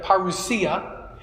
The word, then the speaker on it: parousia: In Greek, we pronounce it parousia.